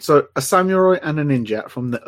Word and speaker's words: samurai: When this happened in the last podcast, he went samurai. It's just samurai. Just say it right. – It's samurai.